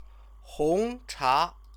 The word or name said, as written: hongcha